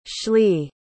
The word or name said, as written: Schley